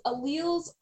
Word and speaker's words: alleles: But I think like alleles, is that right? Is that right? – Yeah. So alleles are, it's just a way to describe like the version of a gene.